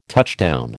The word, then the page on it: touchdown: American English